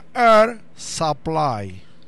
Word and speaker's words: Air Supply: Se pronuncia air supply.